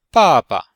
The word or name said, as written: ba4ba5